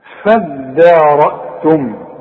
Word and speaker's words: فادارأتم: فادارأتم دي عايزة منك فن في النطق ما تقولش فض الدال الدال والمد بتاعها مرقق هتقول فدا عارف على وزن إيه فدان شوف فدان ارض فدان شوف الدان هتقول لي ايه فضان ولا تقول لي فدان هتقول فدان فدان دي فدان فدا والراء هي المفخمة فدا رأتم والهمزة رأها في الف صغيرة تحت الهمزة يكتب القرآن هكذا.